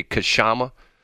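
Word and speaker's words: Kshama: Her name is Kashama. I guess I pronounce it correctly. It's K-S-H-A-M-A. Would you say Kashama? – Yeah, I would. – Her last name is Sawant, S-A-W-A-N-T.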